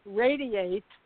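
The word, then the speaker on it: Radi8: I mean, it sounds like radiate, but when you spell it, it's R-A-D-I-8.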